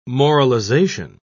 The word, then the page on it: moralization: British English